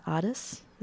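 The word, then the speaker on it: Adès: And I've seen you premiere a number of them, and tonight we'll see here the Gilmore premiere of a new collaboration with Thomas Addis. Is that how you say his name? – Yes,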